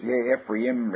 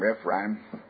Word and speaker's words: Ephraim: Here's Ephraim, Ephraim, however you want to pronounce it.